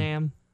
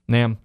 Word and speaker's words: NAMM: How do I say that again? – One. NAMM. – NAMM.